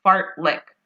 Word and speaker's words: fartlek: And it's basically like fartlek. You're licking a fart.